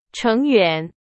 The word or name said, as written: 成员